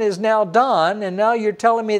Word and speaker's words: Dan: Just like it was in Dan, it's not Dan, it's Don. In G-A-D, the Hebrew name that you would call Gad if you'd pronounce it in English, is pronounced Gad in Hebrew.